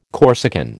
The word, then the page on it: Corsican: American English